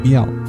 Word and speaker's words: MIAOU: 沉稳的、比较安静的这种、这种、这种后摇了，所以回来以后我的想法就有点改变，然后找的之后也比较刻意的去找一些就是没有爆发的后摇乐队，然后我就碰到了 Miao，就是日本的那个乐队 M I A O U，然后这个乐队呢，它是发音上有点像猫叫 Miao，然后这个乐队也比较有意思，它是由一个女的鼓手组成的，这是在日本。